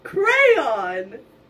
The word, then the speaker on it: crayon: C-R-A-Y, cray, like a crayfish. O-N, on, crayon.